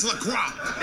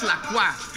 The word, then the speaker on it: LaCroix: Wow, LaCroix? – That's just not LaCroix. – It's LaCroix. – That's LaCroix. – LaCroix.